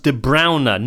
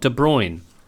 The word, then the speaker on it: De Bruyne: Archon Lobo says it's pronounced De Bruyne, not De Bruyne.